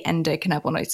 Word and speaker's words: endocannabinoid: Can you tell our listeners really what is the endocannabinoid system and what effect does CBD have on it? – Yeah, and well done for pronouncing that, because that's not an easy one.